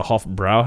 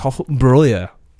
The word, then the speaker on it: Hofbräu: Before I went to Germany, it was I would call it the Hofbräu House, but it's got the umlauts above the A on the second A, so it's Hofbräu House. I believe I'm pronouncing that right now.